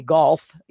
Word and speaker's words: golf: And the one that I have a hard time with is the silent L, like in golf. – Uh-huh. – And most people, of course, just say golf, you know, but technically that's a silent L, is it not?